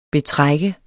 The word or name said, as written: betrak